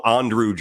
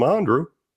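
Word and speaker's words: Andruw: Andrew Jones, for years, by the Braves broadcasters, was called Andrew Jones because his name is spelled a little differently. And we were told in no uncertain terms, yeah, I know, it's Andrew.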